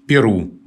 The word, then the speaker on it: Перу: А Флоренция Леонидовна вспоминает историю названия Перу, государство в Южной Америке – она вспоминает, что в течение многих лет употреблялся вариант «перу» с ударением на первом слоге. Он был зафиксирован, например, во втором издании Большой советской энциклопедии.